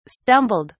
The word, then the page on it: stumbled: British English